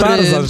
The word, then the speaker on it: Tarzan: Tarzan, że niby RZ, ale nie czyta się jako Ży, tylko Rzy.